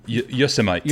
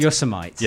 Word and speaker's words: Yosemite: And he's saying, anyone else pronounce Yosemite like Vegemite?